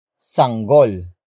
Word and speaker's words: sanggol: It is the possession marker that introduces the owner of the previously mentioned head, and that is sanggol.